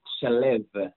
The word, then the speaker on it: Shalev: The name is pronounced Shalev.